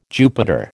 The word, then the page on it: Jupiter: American English